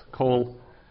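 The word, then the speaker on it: Kol (כל): Kol, that's a short O.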